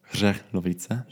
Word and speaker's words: Hřelovice: Genau wie du schon gut zusammengefasst hast, Abschlussseminar Hřelovice, wie spricht man das aus? – Hřelovice. – Hřelovice. Tschechisch hast du schon gelernt.